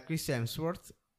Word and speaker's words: Chris Hemsworth: A proposito di citazioni, nel film Avengers Endgame, il personaggio di Thor interpretato da Chris Hemsworth Madonna, come l'ho detto bene? – Infatti, sono stupito.